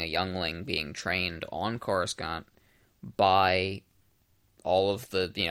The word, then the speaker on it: Coruscant: And he was raised on Coruscant.